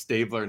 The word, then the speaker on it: Stabler: But I'm certain it's Stabler now.